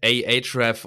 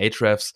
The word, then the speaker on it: Ahrefs: also es heißt AHREF oder AHREFs, wie auch immer man es aussprechen oder möchte. – Oder AREFs, äh, jeder sagt ein bisschen was anderes. – die Amis, sagen, die Amis sagen AHREFs.